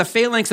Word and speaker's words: phalanx: It's called the phalanx, P-H-A-L-A-N-X.